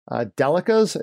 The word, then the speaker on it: Delica: Delicas. I think I said that right.